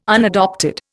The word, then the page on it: unadopted: Indian